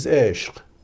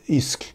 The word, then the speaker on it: ishq: Persian word for love is ishq. Others have hardship in pronouncing it and call it isk.